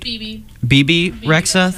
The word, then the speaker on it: Bebe Rexha: And then after that, we listened to Meant to Be by Bebe Rexha, I think I'm pronouncing that right. – Bebe Rexha, thank you.